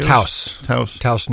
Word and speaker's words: Taos: Is it Down, New Mexico, or how do you pronounce it? – Taos, New Mexico, which any skier worth his salt will know exactly.